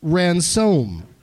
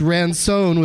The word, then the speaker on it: Ransone: It's Ransone with an N, not Ransom.